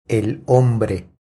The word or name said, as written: El hombre